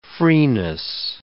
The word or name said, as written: freeness